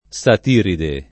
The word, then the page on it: satiride: lingua italiana